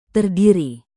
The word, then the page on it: terdiri: indonesian